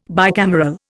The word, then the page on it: bicameral: Indian